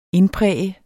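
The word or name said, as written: INDPRÆGET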